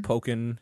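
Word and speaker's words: Pokkén: Pokken? – Pokken, yeah. So like Tekken, but with Pokemon. So Pokken, I guess.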